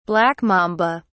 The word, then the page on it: black mamba: British English